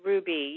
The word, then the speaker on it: Rouxbe: Yes, it's Rouxbe. Yeah, it's spelled R O U X, which is a roux.